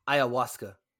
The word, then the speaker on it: ayahuasca: That being said, he says his darkness retreat does not include any use of the ayahuasca. Is that how you pronounce it? – Ayahuasca. – Ayahuasca.